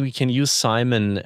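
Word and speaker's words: CIMON: And one of your early projects, I learned, was Simon, or how do you pronounce it? Simon? – Simon, yeah.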